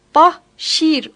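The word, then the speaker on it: Bashir: Bu arada İngilizce'de Başir'in adı Bahşir olarak telaffuz edilir.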